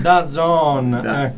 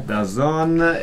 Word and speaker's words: DAZN: Adesso ho detto bene, adesso bene. – DAZN. – DAZN,